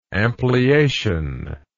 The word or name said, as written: ampliation